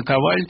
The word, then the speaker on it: Коваль: И, конечно, Коваль, правильное ударение, насколько я знаю, он никогда не ставил себе задачи просто написать детскую вещь.